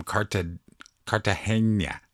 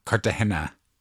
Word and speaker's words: Cartagena: My good pal, all the way from Cartagena. I keep going with the Enya. I say it wrong. Cartagena, Colombia.